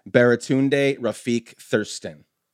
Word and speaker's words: Baratunde Rafiq Thurston: Baratunde Rafiq Thurston.